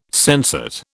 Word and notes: sensate: British English

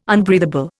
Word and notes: unbreathable: Indian

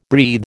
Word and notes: breathe: American English

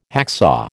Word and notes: hacksaw: American English

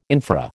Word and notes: infra: American English

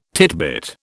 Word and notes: titbit: British English

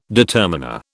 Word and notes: determiner: British English

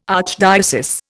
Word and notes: archdiocese: Indian

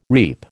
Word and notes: reap: American English

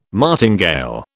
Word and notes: martingale: British English

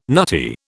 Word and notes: nutty: British English

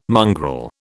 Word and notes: mongrel: British English

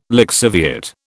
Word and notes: lixiviate: British English

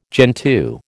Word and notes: gentoo: American English

